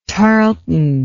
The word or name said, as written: tarlton